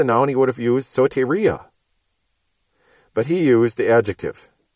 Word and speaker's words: soteria: For if Paul had meant salvation, he would have used the word soteria, S-O-T-E-R-I-A. Again, with a long O and a long E, representing omega and eta.